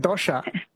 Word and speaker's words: dosai: So she said dosha. – No, they say they spell it like D-O-S-A-I.